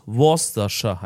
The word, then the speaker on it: Worcestershire: Ich habe das mal hergeleitet, weil es gibt irgendwo eine Stadt, die heißt Leicestershire. Spricht man die aus? – Ah, die aber die schreibt man Leicestershire. Und die spricht man aus Leicestershire. Und wo diese Soße schreibt man Worcestershire. Also muss es Worcestershire heißen.